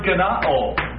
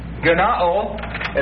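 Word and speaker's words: gennaō: The first word, born, is the Greek word genao. Genao, and that's spelled G-E-N-N-A-O, and what it literally means is to beget or cause to be born.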